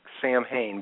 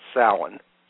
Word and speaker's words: Samhain: It's also, you know, it looks like Samhain, but it's pronounced Samhain. Okay, mm-hmm. – And a lot of people out there have them, you know, misquote that all the time. It's called Samhain.